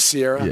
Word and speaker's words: Ciara: It is Sierra. – Yeah, yeah. – Kind of. Was it like Sierra Mist? – It's spelled Ciara. You could say it. It's spelled that way, but it's Sierra.